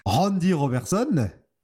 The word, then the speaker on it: Randy Robertson: à savoir Spider-Man qui est colocataire avec Randy Robertson.